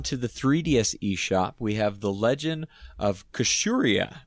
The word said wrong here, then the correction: Kusakari: I'm not sure if I'm pronouncing that right or not. – I don't have it in front of me, unfortunately. Otherwise, I'd totally correct you because I know you got it wrong. – It's Kusakari.